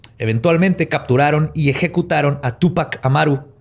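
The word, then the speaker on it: Túpac: Tupac, porque tiene acento en la U.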